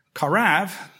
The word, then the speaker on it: qarav: If you want to spell that, it would be Q-O-R-B-A-N. But it is taken from a Hebrew root, a verb, karav, which would be Q-A-R-A-V or B. The B-V sound is the same letter in Hebrew.